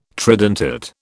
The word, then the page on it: tridentate: British English